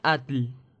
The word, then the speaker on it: Atl: Es por lo demás extraño encontrar de ambos lados del océano el mismo símbolo, que se pronuncia Atl, raíz de la palabra Atlántida.